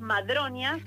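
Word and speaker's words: Madroñas: Taller Las Madronias. – Ah, Las Madronias, madre. Sí, perdón, lo dije mal, Las Madronias. – No, no hay problema. Es como madre y doña.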